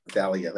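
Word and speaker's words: Zaliya: Right. Zalia, that's how you say it.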